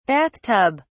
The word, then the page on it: bath tub: British English